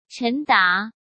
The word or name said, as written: Chen Da